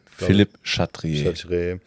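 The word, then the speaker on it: Philippe Chatrier: Philipp, ich kann es nicht aussprechen, Philipp Cartier. – Philipp Chatrier.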